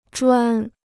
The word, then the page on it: 砖: zhuān